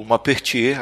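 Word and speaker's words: Maupertuis: É como, como o Pena tinha dito, o Mapertier, agora falei certo, ele, ele era um grande defensor da, dessa religiosidade dentro da ciência e tal.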